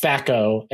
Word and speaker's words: THAC0: Yeah, it's THAC0. So it's THACO and it's chance.